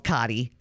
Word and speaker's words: Coddie: And there's something called a Cotty. Poor man's crab cake. – Okay. I'm a poor man. – Well, this is yours. I think it's called Coddie. C-O-D-D-I-E. I'm saying that right, right? – Yeah.